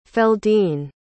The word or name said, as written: feldene